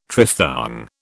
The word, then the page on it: triphthong: British English